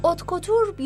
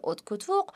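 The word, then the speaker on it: haute couture: اتکوتور یا دوخت سفارشی سطح بالا با تلفظ صحیحتر و فرانسوی اتکوتوق به مد سطح بالایی گفته میشه که به صورت دوخت سفارشی و دستدوز تهیه میشه.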